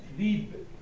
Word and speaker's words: ذئب: راس راس مثلا کلمه ذئب میشه چی؟ ذیب